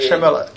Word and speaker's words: Shyamalan: To je Shyamalan, ne? Nebo něco takového? – Ne. Shyamalan. – I na Wiki je to napsáno v výslovnosti Shyamalan.